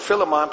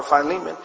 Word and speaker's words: Philemon: And he writes a letter to Philemon, or Philemon. People pronounce it differently, but I call it Philemon.